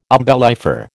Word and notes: umbellifer: American English

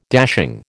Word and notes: dashing: American English

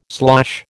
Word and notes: slosh: American English

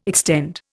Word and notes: extend: Indian

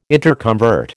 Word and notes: interconvert: American English

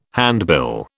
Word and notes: handbill: British English

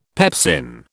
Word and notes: pepsin: British English

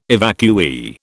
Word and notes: evacuee: British English

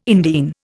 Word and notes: indene: Indian

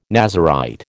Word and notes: Nazarite: American English

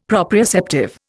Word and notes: proprioceptive: Indian

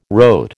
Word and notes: rote: American English

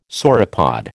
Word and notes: sauropod: American English

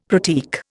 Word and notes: pratique: Indian

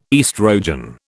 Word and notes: estrogen: British English

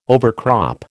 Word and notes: overcrop: American English

